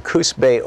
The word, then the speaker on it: Coos Bay: Catstone, watching Free Speech TV in Coos Bay, Oregon.